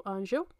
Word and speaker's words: Angel: And we'll be talking about the life and death of Zuzu Anjo, or Anjo, if you want to say it like that. But in Portuguese, we call her Zuzu Anjo, which happened during the Brazilian dictatorship.